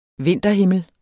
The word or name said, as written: vinterhimmel